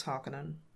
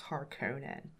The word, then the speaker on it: Harkonnen: Sorry, I mean Harkonnen. Can't pronounce Harkonnen. It's Harkonnen.